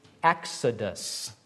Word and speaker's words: exodus: The Greek word for departure is exodus. That's how you say it in Greek.